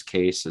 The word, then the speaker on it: CAIS: And we say CAIS, right? – It's pronounced CAIS, but it is capital CAIS.